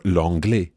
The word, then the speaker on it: l'anglais: But in the south of France, you'll hear a sound that sounds more like the English pay or day or may. So that would become je faisais or l'anglais, just as Maëlys pronounces the word for English.